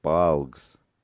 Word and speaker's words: bilge: its bligers. – Bligers. – Bligs. – How do you spell that? – B-L-I-G-E-S. No, B-I-L-G-E-S. Bilge.